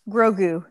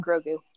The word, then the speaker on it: Grogu: I got my very own Grogu. Is that how you say his name? I always say it wrong. – No, you got it. – Okay. Yeah, I got my very own Grogu.